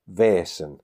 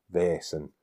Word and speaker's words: Vaesen: Now, the way that the word Vaesen is written in its native Swedish, the A and the E are conjoined, which, as I understand it, is pronounced as an extended E sound, so it's air. So it should be pronounced Vaesen, which to me sounds like I'm just saying it with a Yorkshire accent.